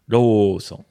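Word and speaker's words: Rōson (ローソン): Like Lawson is Rōson. – Rōson.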